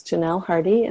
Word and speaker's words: Janelle Hardy: That's Janelle Hardy, and that's spelled J A N E L L E H A R D Y.